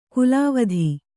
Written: ಕುಲಾವಧಿ

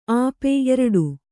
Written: ಆಪೆ

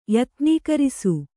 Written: ಯತ್ನೀಕರಿಸು

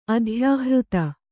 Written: ಅಧ್ಯಾಹೃತ

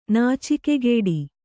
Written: ನಾಚಿಕೆಗೇಡಿ

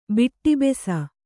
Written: ಬೆಟ್ಟುಬ್ಬಸ

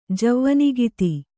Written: ಜವ್ವನಿಗಿತಿ

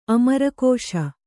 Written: ಅಮರಕೋಶ

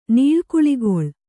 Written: ನೀಳ್ಕುಳಿಗೊಳ್

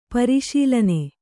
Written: ಪರಿಶೀಲನೆ

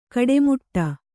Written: ಗಟ್ಟಿಮುಟ್ಟು

